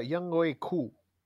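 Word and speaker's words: Younghoe Koo: Realmente creo que se pronuncia Yang Q, Así que creo que es así más bien.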